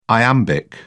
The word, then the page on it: iambic: British English